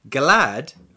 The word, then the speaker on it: glad: And in Svenska, glad rhymes with sad.